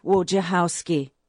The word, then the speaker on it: Wojciechowski: Steve Wojciechowski. – It's like, where's your house key, but put Woj on front, okay? – That's great.